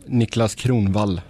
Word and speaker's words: Niklas Kronwall: Nicholas Cronwall, retiring. – Yep. – How do you say his name in Swedish? – Niklas Kronwall. – Yeah, see, that's not how I said it.